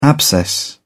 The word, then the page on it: abscess: British English